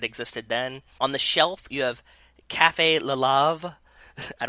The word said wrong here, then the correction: La Llave: Probably La Llave. – Cafe La Llave. – La Llave, because I think the double L is like a Y, so like La Llave.